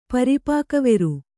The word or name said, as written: ಪರಿಪಾಕವೆರು